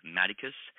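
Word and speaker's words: Madicus: Maticus, J space T space Maticus in quotation marks, very searchable.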